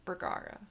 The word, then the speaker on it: Bergara: There's the Bugaras, because it's Bergara is how you say his last name.